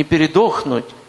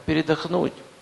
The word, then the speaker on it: передохнуть: Дайте передохнуть. Не перепутайте ударение в слове «передохнуть». Не передохнуть. Это в пустыне с ними тем произошло. Они умерли, понимаете? А передохнуть.